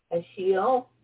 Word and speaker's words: Ashiel: Ashiel? – Yeah.